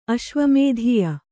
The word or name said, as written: ಅಶ್ವಮೇಧೀಯ